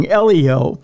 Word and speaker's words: LEO: The FAA said in a report to Congress, the dramatic rise of non-geostationary satellites, particularly those in ELO, pose not ELO, but I was going to say, I'm sorry. Electric Light Orchestra. – No, LEO. I said it wrong. LEO, not ELO.